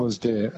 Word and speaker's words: Alasdair: Alasdair. A-L-A-S and then D-A-I-R.